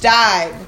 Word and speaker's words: Steib: S-T-E-I-B. – Uh-huh. – Let's see. That's my maiden name. And it's pronounced Stibe. – Right. – And it's so powerful. It's like, oh, what a name. Stibe.